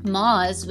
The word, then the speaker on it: Maus: The novel is entitled Maus. It's M-A-U-S. I'm not sure how else you would pronounce that.